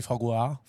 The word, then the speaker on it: foie gras: That's not how you say it. – Oh, okay. – It is actually foie gras.